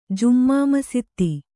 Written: ಜುಮ್ಮಾಮಸೀದಿ

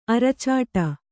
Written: ಅರಚಾಡು

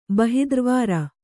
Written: ಬಹಿದ್ರ್ವಾರ

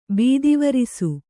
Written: ಬೀದಿವರಿಸು

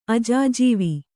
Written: ಅಜಾಜೀವ

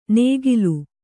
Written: ನೇಗಿಲು

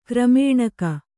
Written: ಕ್ರಮೇಣಕ

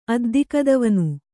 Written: ಅದ್ದಿಕದವನು

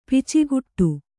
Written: ಪಚ್ಚುಗೂಟ

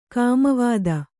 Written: ಕೋಮುವಾದ

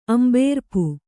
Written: ಅಂಬೇರ್ಪು

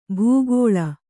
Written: ಭೂಗೋಳ

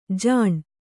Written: ಜಾನು

